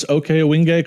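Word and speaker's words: Ohkay Owingeh: Now, did I pronounce Okeowingay correctly or did I butcher it? – You did it perfectly. Yep.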